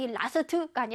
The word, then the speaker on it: last: 자, 이 last가 아니라 last, 길게 last.